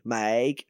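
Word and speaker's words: Miike: It's by, I don't know how to pronounce it, Mika Snow. It's M-I-I-K-E, Mike Snow.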